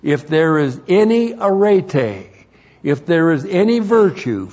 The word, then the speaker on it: arete: A-R-E-T, long E, arete.